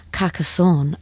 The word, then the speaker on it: Carcassonne: They went to see Franz Ferdinand perform, and I have looked up how to say this, at the Festival de Carcassonne. Apparently, that's how you say it, according to Google, on Monday evening.